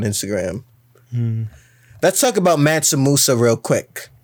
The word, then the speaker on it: Mansa Musa: M-A-N-S-U-M-U-S-A. I'm pretty sure it's Mansa Musa.